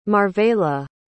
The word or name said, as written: marvela